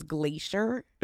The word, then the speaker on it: Glaisher: He was obsessed with weather and his last name was Glacier. – But it's spelled G-L-A-I-S-H-E-R. – Okay. – It's like phonetically Glacier.